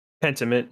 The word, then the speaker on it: Pentiment: Pentiment. Did I pronounce this game right? – Pentiment, coming from Obsidian Games, coming out sometime in November.